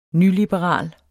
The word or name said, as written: nyliberal